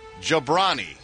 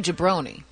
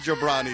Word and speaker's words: Jobrani: Maz Jobrani. – Why are you pausing? – I've been getting some stick from people saying I'm not pronouncing his name correctly. – Well, okay. Don't say Jabroni.